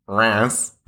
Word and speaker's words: Reims: It looks like Reims or Reims, but it's Reims.